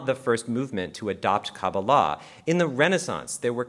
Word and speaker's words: Kabbalah: Now, they'll often pronounce it Kabbalah, and while I said that I use the more Israeli / Sephardi Kabbalah with the emphasis on the third syllable, Ashkenazi Jews did refer to it as Kabbalah.